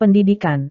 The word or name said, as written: pendidikan